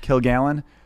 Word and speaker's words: Kilgallon: It's really one word, Kilgallen. But I've always kind of emphasized the kill.